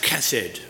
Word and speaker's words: chesed: But I think I want to draw our attention today more to an Old Testament word, a Hebrew word, and the word hesed. And you'll find this also often used in the Old Testament quite a lot to describe God's covenant love. So it's pronounced chesed with a K, chesed.